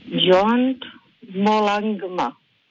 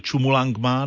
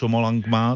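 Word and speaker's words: Chomolungma: Aha, protože Mount Everest se jmenuje buď Chumulangma nebo Chomolangma, to je obojí správně.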